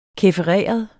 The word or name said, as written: kæfereret